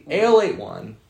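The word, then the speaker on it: Ale-8-One: I also got a drink from Kentucky called Alate One, but it's spelled like so weird. It's ale, like A-L-E, and then it's the letter 8 and 1. – Oh. – Because it's a late A-L-8 one. Alate One.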